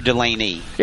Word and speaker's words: Delane: That was – now, does he pronounce it Delane or Delaney? – It's Delane. – Delane, okay.